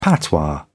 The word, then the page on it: patois: British English